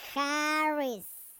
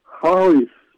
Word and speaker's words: Harris: Como decir jarra, pero vas a decir Harris. – Harris. – Ándale. Muy bien.